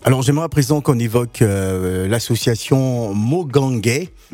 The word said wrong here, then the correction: Mohangé: Mohangé, j'ai mal prononcé, hein, c'est vraiment pas un bon gabonais.